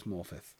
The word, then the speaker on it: Morfydd: I believe it's Morfydd. Morfydd. So I think it's Morfydd Clark.